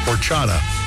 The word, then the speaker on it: horchata: Horchata.